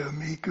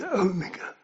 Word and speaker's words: Omega: Shouldn't say Omega, really, because it's mega as in megaphone. It's a long O.